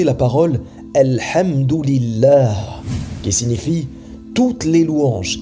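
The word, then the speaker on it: Alhamdulillah (الحمد لله): Ils disent par exemple amdullah et ceci signifie, si on traduit mot à mot, le prolongement d'Allah.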